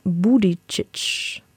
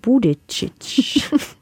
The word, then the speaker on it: Buttigieg: Buttigieg. – Sag's nochmal. – Buttigieg.